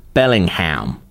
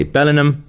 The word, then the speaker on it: Bellingham: Please pronounce Bellingham the way we Americans do. Bellingham. I know you're tempted to say Bellingham, but you must resist that.